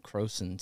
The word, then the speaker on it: Crowson: It's spelled Crowson, C-R-O-W-S-O-N.